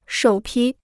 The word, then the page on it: 首批: shǒu pī